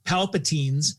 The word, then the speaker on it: Palpatine: Turns out it's Palpatine's. – Palpatine. That's what I said. I just said it right now.